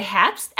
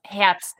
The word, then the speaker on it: Herbst: Well, in German you say Herbst, and it actually means the season fall. So how do you say it now, again? Herbst. – Herbst. Herbst. Okay, that's what how you would totally say it in German.